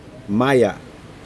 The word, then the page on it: maya: Aneityum